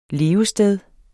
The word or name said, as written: levested